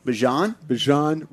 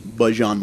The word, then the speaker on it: Bijan: How you pronounce my name is Bijan. For everybody that's out there, I know a lot of people say Bijan, but it's Bijan.